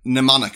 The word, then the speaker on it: mnemonic: Mnemonic, sorry, I've been saying it wrong. Mnemonic.